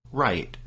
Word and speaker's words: Raith: And Wright's name is spelled R-A-I-T-H, but as we understand it, his name is pronounced Wright.